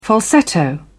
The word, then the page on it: falsetto: British English